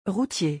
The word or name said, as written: routier